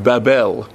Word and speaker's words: Babel: But I know this appears to be the way the international community has accepted Alejandro González Iñárritu's film Babel. It's Babel, guys. That's the way it is.